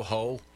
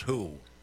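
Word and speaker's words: Hou: So, you go, Ho. – Yeah. Oh. – It may be pronounced who, but it sure looks like ho to me.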